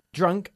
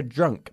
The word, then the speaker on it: drunk: Drunk significa borracho y también es el participio del verbo irregular to drink, beber, ¿ok? Y se pronuncia drunk, bajando la mandíbula, no drunk, ¿ok? – Okay.